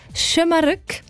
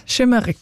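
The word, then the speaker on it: Şımarık: Also man sagt «Schömerück» und das heisst übrigens so ein bisschen frech ungezogen.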